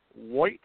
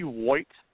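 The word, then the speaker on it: Woit: Richie White, I think we'll say is how that last name is pronounced, Richie White, W-O-I-T, 5'8",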